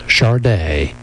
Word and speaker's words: Sade: The name is pronounced Sade. It is spelled S-A-D-E.